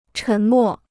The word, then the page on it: 沉默: chén mò